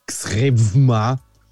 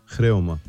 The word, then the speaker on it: Xrewma: Es wird X-R-E-W-M-A geschrieben. – Das könnte man dann versuchen zu lesen wie Xrevma, aber es heißt Chreoma. – Chreoma.